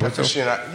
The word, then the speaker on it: aficionado: Aficionado. You said it right.